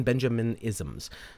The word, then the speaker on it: Benjaminisms: I'm picking up the Owen Benjamisms. I think I kind of like them. but uh, Benjamin ben, There we go. I gotta say that right. The Owen Benjaminisms.